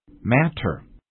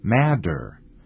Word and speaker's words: matter: Number one, M-A-T-T-E-R, matter. Matter is often pronounced madder, as in, what's the matter?Number two, A-T-O-M, atom.